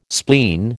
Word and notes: spleen: American English

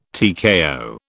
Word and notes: TKO: British English

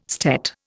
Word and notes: stet: Indian

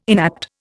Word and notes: inapt: Indian

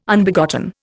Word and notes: Unbegotten: Indian

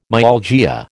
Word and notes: myalgia: American English

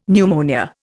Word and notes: pneumonia: Indian

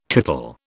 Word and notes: kittle: British English

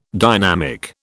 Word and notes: dynamic: British English

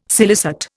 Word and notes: scilicet: Indian

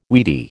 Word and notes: weedy: American English

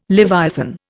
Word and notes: leviathan: Indian

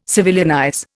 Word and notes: civilianize: Indian

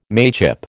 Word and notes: mateship: American English

